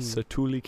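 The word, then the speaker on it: Satu'li: Satuli. – What? – Satuli Canteen. – Satuli Canteen.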